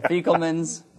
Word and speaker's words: Fecklemans: It's a long E. Mm. Like you. So Fecklemans.